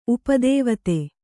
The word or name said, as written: ಉಪದೇವತೆ